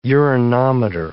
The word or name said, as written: urinometer